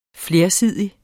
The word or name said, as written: flersidig